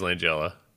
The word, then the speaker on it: Langella: But at the time, like, I, honestly, I, I, will say this much: Frank Langella is one of my, and I, I would say Langella. I don't. I guess I'm wrong. – I, uh, I think it is Langella.